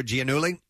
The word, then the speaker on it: Giannulli: Massimo Giannulli? – Yes.